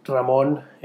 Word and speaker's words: Ramon: This is episode numero uno, and I'm your host, Ray, or Ramon. No, actually, my name's pronounced Ramon in Spanish, and my parents give me a hard time when they hear me pronounce my name in English, which is kind of funny, but I don't mind.